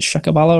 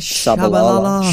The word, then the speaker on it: Tshabalala: One 0 Shaka was it Shakabala or whatever his name was? – Tshabalala.